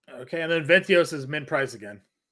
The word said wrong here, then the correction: Vientos: Is it, how do you say his name? Ventios? Vientos? – Vientos.